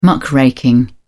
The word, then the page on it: muckraking: British English